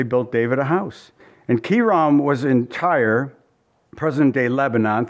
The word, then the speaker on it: Hiram: And Hiram, or literally it's pronounced Kiram, but we all say Hiram, king of Tyre, sent messengers to David and cedar trees and carpenters and masons, and they built David an house.